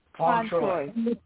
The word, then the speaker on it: Fauntroy: Fauntroy. – Like Leroy Fauntroy? – Leroy Fauntroy.